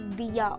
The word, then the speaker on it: Dia: Anyway, because of that, people get confused that my name is Diak. So no, my name is Dia only, D-I-A. It's pronounced as Dia, and that's my name.